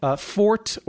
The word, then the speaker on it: forte: Fort as an English pronunciation is long standing in our language.